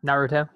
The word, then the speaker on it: Naruto: And I'm going to butcher this name, Naruto. – Naruto, yep.